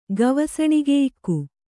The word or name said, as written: ಗವಸಣಿಗೆಯಿಕ್ಕು